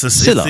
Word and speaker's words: Szilard: Sure, I never get this Szilard. – Right. – It's Szilard. It's the S and the Z together, Kev. – Yeah. – It's the silent Szilard.